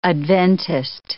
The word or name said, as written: Adventist